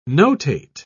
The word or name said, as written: notate